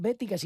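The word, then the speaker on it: Baetica: No es bética, sino baética, aunque la pronunciación sea bética.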